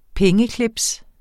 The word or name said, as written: pengeclips